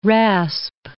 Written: rasp

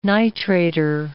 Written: nitrator

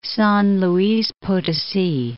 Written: San Luis Potosi

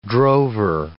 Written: drover

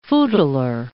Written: footler

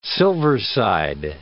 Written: silverside

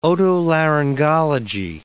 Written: otolaryngology

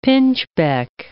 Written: pinchbeck

